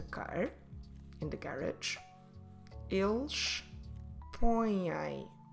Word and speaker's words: põem: our verb form in Portuguese is põem. Eles põem. Como se escreve põem? P, O, contil, E, M. Põem. Very nasal. So on this one, to indicate that it is nasal, we have the accent, til, and we also have the M.